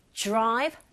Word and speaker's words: drive: Great drive, nothing stop. 내용어고요. 자, 이때 드라이브로 발음하지 마시고 drive, drive. D sound, R sound 같이 붙어 있을 때 약간 J에 가깝게 소리가 들리거든요. Drive.